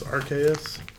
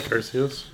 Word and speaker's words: Arceus: Yeah, what's the right one? I say it wrong. I say Arceus, right? – I like Arceus. – But the movie canon was Arceus? – Arceus. – Arceus. Our chaos. – I like a hard C.